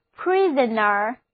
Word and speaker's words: prisoner: 我们在 prison 后面加上表示人的 er 后缀，那么 prisoner P-R-I-S-O-N-E-R prisoner， O 字母在这里还是不发音，单词就读作 prisoner 囚犯。